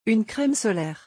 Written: Une creme solaire

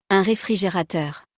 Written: Un refrigerateur

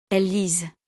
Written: elles lisent

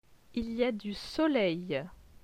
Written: Il y a du soleil

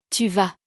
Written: tu vas

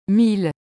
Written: 1000

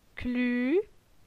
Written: Clu

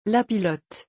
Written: la pilote

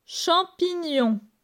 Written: Champignon

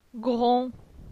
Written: Gron

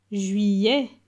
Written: Juillet

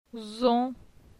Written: Zon

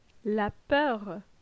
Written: La peur

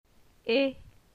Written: Et

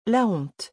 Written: la honte

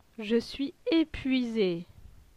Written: Je suis epuise

